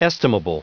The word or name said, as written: estimable